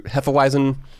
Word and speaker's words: Hefeweizen: which is a grapefruit Hefeweizen. I'm going to say it properly with a V instead of the W.